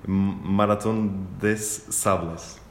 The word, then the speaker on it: Marathon des Sables: corrijo-me se eu estiver errado na, na pronúncia, Marathon des Sables. – Precisamente, esse de está ótimo. Esse francês está 2016, a Maratona de Sábado.